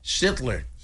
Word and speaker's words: Schlitter: El nombre es raro de pronunciar, es Schlitter. – Exacto, es alemán.